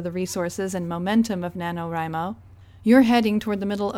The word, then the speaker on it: NaNoWriMo: As I speak, hundreds of thousands of writers are in the middle of National Novel Writing Month, or what is affectionately known as NaNoWriMo. Some people say NaNoWriMo. However you pronounce it, November is a month where writers have signed up with the goal of completing a novel draft of 50,000 words.